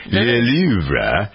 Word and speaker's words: Louvre: Le Louvre. There's an R-E on the end of it. Le Louvre.